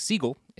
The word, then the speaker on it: Siegel: Steven Frederick Seagal, Steven Frederick Siegel is the original pronunciation of his name, so we'll get to that in a second, was born on April 10th, 1952.